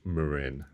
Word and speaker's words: Marin: He is the International Marketing Director of Marin Bikes, and is also the man who knows the difference between Marin and Marin, because obviously we all call it Marin Bikes, but it's not. – We do. No, it is pronounced, technically it is pronounced Marin.